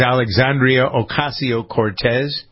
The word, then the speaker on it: Alexandria Ocasio-Cortez: I believe her name is Alexandria Ocasio-Cortez. Am I pronouncing it correctly? – That is correct.